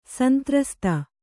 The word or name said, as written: ಸಂತ್ರಸ್ತ